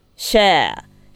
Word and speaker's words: Cher: How do you pronounce her name? Sh Share? Share. CHER と書いてシェアさんのね、名曲ですけれども。